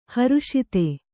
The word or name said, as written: ಹರುಷಿತ